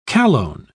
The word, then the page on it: chalone: British English